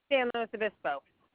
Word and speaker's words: San Luis Obispo: San Luis Obispo.